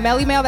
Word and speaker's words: Mellie Mel: They can find me on Melly Mel, that's spelled M-E-L-L-I-E, M-E-L on Facebook, and on Instagram, Cali Love, C-A-L-I-L-O-V-E-83, and then on Twitter, MellyMel_radio.